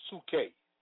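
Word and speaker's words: psuche (ψυχή): The word soul in Greek is psyche, pronounced psuche, from which we derive the word psyche, dealing with the mind.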